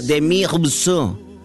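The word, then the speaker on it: Demis Roussos: Russos, se escribe Russos con dos S, pero se pronuncia Demi Russo.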